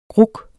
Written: gruk

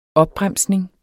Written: opbremsning

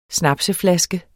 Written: snapseflaske

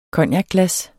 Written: cognacglas

